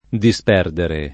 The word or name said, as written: disperdere